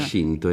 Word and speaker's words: Shinto: Ecco, Shin, volta lei ce l'aveva detto, Shin da Shinto è la pronuncia alla cinese, quindi Shinto è la via dei Kami, però Kami no Michi, pronunciato alla giapponese, ma ecco.